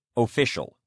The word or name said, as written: official